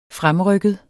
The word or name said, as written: fremrykket